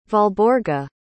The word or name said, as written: valborga